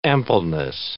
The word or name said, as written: ampleness